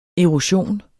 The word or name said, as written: EROSIONER